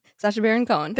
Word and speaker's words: Sacha Baron Cohen: Sacha Baron Yang. – Yeah. No, how do you say his name? – Sacha Baron Cohen.